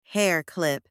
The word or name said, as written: hair clip